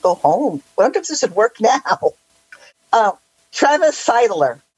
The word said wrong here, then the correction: Sittler: This is Travis Sittler. That's, you pronounce it Sittler.